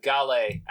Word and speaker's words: Gale: Gail Bosamonde. I may have mispronounced that. Gale, actually, is how it's pronounced.